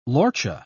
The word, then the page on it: lorcha: British English